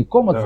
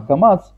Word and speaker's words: камац: Не комац, да. А камац. Вот, это так, это звучит почти одинаково в сефардском произношении, вот, и поэтому здесь нужно отдельно указать, в чем все-таки между ними разница, как раз об этом указывает Рабейну Бихай.